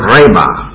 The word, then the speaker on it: rhema: It is the word rhema, R-H-E-M-A, with a long E, rhema.